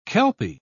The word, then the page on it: kelpie: British English